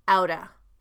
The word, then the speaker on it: out of: So notice the American pronunciation of out of, outa, outa place, out of place, outa place. Out of becomes outa. It would be spelled O-U-T-T-A.